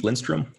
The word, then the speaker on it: Lindstrom: So my name is Steve Lindstrom.